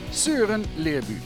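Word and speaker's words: Søren Lerby: Søren Leerbu, ja, zo spreek je dat uit.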